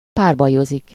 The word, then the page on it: duel: /ˈduː.əl/; /ˈdju.əl/; /ˈdu.əl/; /ˈdjuː.əl/